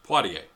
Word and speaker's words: Poitier: Poitier.